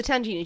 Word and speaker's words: tangena: Now, I believe it's pronounced Tangina. T-A-N-G-E-N-A. So the Tangina, do you know what it is? – No, I can't say I do.